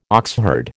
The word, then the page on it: oxherd: American English